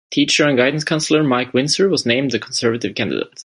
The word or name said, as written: Conservative